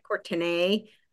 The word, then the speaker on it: Courtenay: So the main one is the Courtenay Turner podcast, and that is spelled like Courtenay.